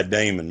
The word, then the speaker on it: Damond: Yeah, Damond, man. – Damond, all right. I want to butcher your name. – Like a little twang. It's Damond.